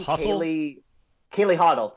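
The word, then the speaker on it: Kaylee Hottle: Kaylee Hoddle, thank you.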